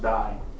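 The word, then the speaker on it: die (δεῖ): Now, going back to verse 4 in Hattu, there's two words that could be used for that in the Greek, and the one is DEI, or pronounced die.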